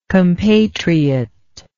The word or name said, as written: compatriotism